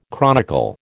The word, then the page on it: chronicle: American English